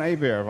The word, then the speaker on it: Hebert: And then to my right is John Hebert. Have I pronounced that correctly? – Yes, you did, sir. – It's John. – Yes. – I thought so. Hebert, I know, because it's spelt the Cajun way.